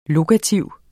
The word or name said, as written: lokativ